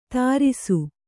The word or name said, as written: ಧಾರಿಸು